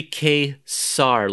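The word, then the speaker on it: Ka-Zar: you pronounce this word is it kazar uh, kazar it's a, it's it's actually kazar like the Z is an S sound,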